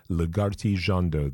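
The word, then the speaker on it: Lagartijeando: creating quite a lush electronic sound from Lagarti Jando. I will spell that full name for you because it's a weird one. It's L-A-G-A-R-T-I, Lagarti, and it's one word, Jando, J-E-A-N-D-O, Lagarti Jando.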